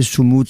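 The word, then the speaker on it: Sumud: Soumoud, ça veut dire résister, endurer, persévérer, espérer, etc.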